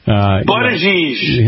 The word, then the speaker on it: Buttigieg: But even, Pete Buttigieg. – Buttigieg.